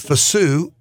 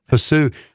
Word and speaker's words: Fayssoux: is Fasu Starling McLean, And Fassou is, it's spelled F-A-Y-S-S-O-U-X. It's the coolest name. – Okay. – Fassou.